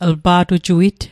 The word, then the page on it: lpa'tujuit: l'pa'tujuit